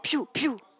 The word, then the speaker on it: Pfiou: Piu, piu. C'est piu, piu. – Comment ça s'écrit? – P-F-I-O-U.